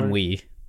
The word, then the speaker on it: ennui: the constant sense of isolation and inui, and is it inui? Inui? How do you say that? – Ennui. – Ennui. Wasn't even close.